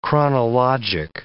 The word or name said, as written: chronologic